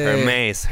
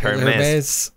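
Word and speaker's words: Hermès: Hermes, Hermes.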